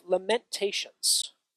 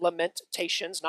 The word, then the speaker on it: Lamentations: So go to the book of Lamentations. Yes, it is pronounced lamentations, not lamentations. That's a southern pronunciation and not southern Jerusalem. Lama, there is only one A there in the beginning of the word.